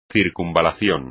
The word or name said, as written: circunvalación